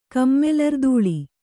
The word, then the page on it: ಕಮ್ಮೆಲರ್ದೂಳಿ: kannada